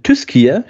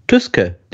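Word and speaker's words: Tyskie: Tyskier spricht man Tyske, nicht Tyski aus.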